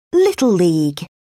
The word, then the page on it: little league: British English